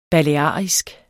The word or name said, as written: balearisk